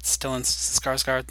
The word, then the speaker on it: Stellan Skarsgård: And, am I pronouncing it right? Stellan Skarsgård? – That's right, I think.